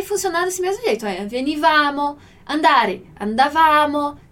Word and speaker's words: venivamo: Como é que eu sei que o acento que aqui não existe, mas que a sílaba forte vai ser no vá, venivamo.